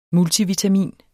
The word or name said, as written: multivitamin